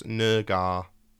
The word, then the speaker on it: Naggar: And it was a bit of a controversy because his name is spelled Naggar. So, you know, just visualise that yeah. – out. And it was like, guys, can you chill out? It's Nergar. So he's cleared that up. That's how to pronounce his name, just in case you need it for the future.